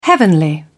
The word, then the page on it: heavenly: British English